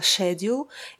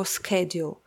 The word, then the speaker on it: schedule: Это такие слова, как график расписания, которое я произносила schedule, и это британский вариант. Американцы же произносят его schedule.